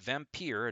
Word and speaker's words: Vampyr: Vampyr started out as good, and yes, I'm going to keep pronouncing it Vampyr and not Vampire because I learned English.